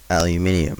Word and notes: aluminium: Australian English

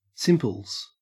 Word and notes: simples: Australian English

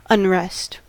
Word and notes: unrest: American English

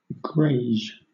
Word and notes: greige: British English

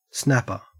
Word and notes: snapper: Australian English

